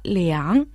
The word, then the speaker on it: 两: Слово «лян» читается третьим тоном, означает «два», «оба».